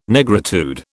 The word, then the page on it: Negritude: British English